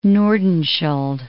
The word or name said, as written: Nordenskjold Sea